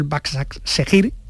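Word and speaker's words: Başakşehir: pero el Baksak Segir, o como se pronuncie, no llegó a lo mínimo. – Está bien, está bien, pronunciado.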